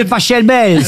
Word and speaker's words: Pachelbel: Catelloni, c'est Pachelbel. – S'il est allemand, ça se dit Pachelbel.